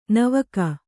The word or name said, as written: ನಾವಿಕ